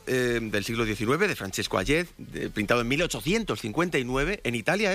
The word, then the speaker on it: Hayez: Hayez. – Hayez.